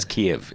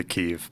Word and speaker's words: Kyiv: I guess it's Kiev. It's the Ukrainian pronunciation of it. – Yeah, if you want to be very Ukraine focused, you would say Kyiv.